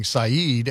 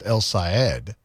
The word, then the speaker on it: El Saeed: I've been saying Saeed, as many people on the media have been saying, but apparently it's pronounced El Saeed, like Mr. Ed.